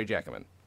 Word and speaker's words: Jacquemin: Welcome from the UK, Andre Jacquemin. I said I did it with the soft J. Let me try it again. Andre Jacquemin, thank you for being here.